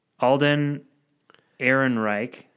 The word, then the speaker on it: Alden Ehrenreich: Alden Ehrenreich.